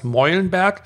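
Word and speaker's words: Muilenburg: Und wenn man dann dazu hört, dass der ehemalige Boeing-Chef, der 2015 Meulen, ah, wie spricht man den aus? Meulenberg, also er wird Muilenberg geschrieben. Manchmal ist es mit den amerikanischen Namen ja nicht so einfach. Ja. Dennis Meulenberg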